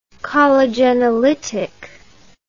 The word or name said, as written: collagenolytic